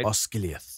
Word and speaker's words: Osgiliath: Frodo and Sam and Gollum go with Faramir to the Battle of Osgiliath. I think I said that right. – Osgiliath.